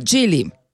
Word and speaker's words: Geely: Geely.